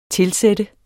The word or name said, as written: TILSATTES